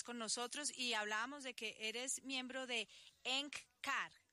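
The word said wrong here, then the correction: Eckankar: Se pronuncia ECANCAR. S-K-A-N-K-A-R. Significa ECANCAR. Colaborador. Significa colaborador con Dios. – Perfecto. ECANCAR.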